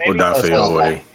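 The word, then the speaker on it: Odafe Oweh: And then he drafts Marquise Brown, Patrick Queen, Rashad Bateman, Dofe Owe. Am I saying that right? Maybe. – Odafe Owe. Yeah.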